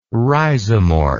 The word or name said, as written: rhizomorph